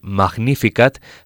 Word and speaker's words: Magnificat: El acento en la vocal A de este coro, eh, en la primera sílaba de Magnificat, se hace presente en todo momento.